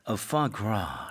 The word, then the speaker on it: foie gras: 푸아그라가 아니라 발음이 역시 우리 프렌치 발음이 아주 멋진 쌤이 foie gras. – Foie gras. – Right. – Okay, well, this is not so it's interesting. It's spelled F-O-I-E and then gras is actually G-R-A-S, but that S is silent.